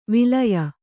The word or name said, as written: ವಾಲಾಯ